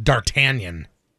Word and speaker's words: D'Artagnan: Apparently the name that person is talking about naming their son, it's pronounced D'Artagnan.